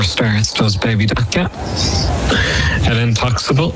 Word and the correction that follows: El Intocable: or El Intocable, perhaps to be a better pronunciation.